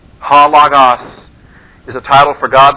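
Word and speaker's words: Ha Logos (ὁ λόγος): The definite article Ha. It's just an omicron and a short O. Ah sound, but it does have the rough breather, so it's H-O, Ha. And then Logos. Lambda, omicron, gamma, omicron, sigma. L-O-G-O-S. The accent is on the first omicron. Ha Logos.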